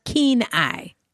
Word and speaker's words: Kenai: Kenai is pronounced keen eye, fabulously enough.